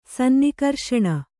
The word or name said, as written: ಸನ್ನಿಕರ್ಷಣ